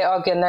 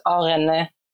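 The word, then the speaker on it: Oghene: I know the GH is very hard to pronounce for non-Urhobos, but the GH is pronounced like an R, re, or re. But I know because it's hard for so many people, especially for like native English speakers, they just say Ogene, and I guess that's fine, but the actual Urhobo pronunciation is Orene Re.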